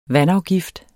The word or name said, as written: vandafgift